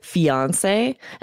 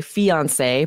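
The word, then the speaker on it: fiancé: And there's tiny difference in pronunciation. You'll hear it both ways. Some people say fiance and some people say fiance. Both are correct.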